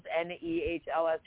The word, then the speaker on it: Nehls: Is it Nels or Niels? N e h l s.